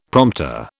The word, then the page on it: prompter: British English